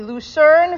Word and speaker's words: Lucerne: The Zilbersteins were newly married when the Rosh Yeshiva, how do you pronounce this town? L-U-C-E-R-N-E, Lucerne, Switzerland? Yeah, okay.